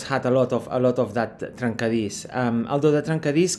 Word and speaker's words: trencadís: Then I read about trencadís. Am I pronouncing that properly? – Yes, trencadís.